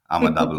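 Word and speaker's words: Ama Dablam: I just like to say it, Amadablam. I just kept with him and saying it wrong, and still didn't make us. – No, you've pronounced it pretty well, Amadablam.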